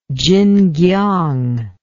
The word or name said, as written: tsinkiang